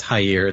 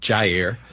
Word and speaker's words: Jair: First of all, talking of disinformation, I misspoke earlier when I said that Bolsonaro's first name was Jair. It's actually pronounced Jair.